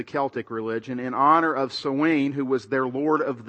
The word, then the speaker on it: Samhain: It's spelled S-A-M-H-A-I-N, but it's pronounced Samhain.